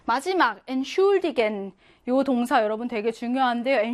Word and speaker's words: Entschuldigen: 태 S체하가 하나가 아니라 태 따로, S체하 따로야. 그래서 뭐, 태는요, 그냥 트로 발음하겠죠? 그리고 S체하는요, 슈로 따로 발음하겠지? 이렇게 취가 아니라 트, 슈로 따로 발음합니다. Entschuldigen 이렇게요.